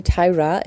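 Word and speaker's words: Tyria: Tyra schreibt man hier mit einem IA am Schluss, aber alle nennen sie Tyra. Deswegen werde ich das jetzt hier auch tun. Also es ist kein Formfehler, sondern spricht man so aus.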